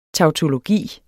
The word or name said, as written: tautologi